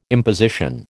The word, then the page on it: imposition: American English